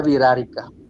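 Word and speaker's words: Wixárika: el nombre correcto es Wirarica, bueno, se pronuncia Virarica, que en su lengua significa nuestra gente, ellos tienen un rito donde van una vez al año a la playa, San Blas Nayarit, y cantan.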